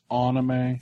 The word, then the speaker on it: anime: It's pronounced anime.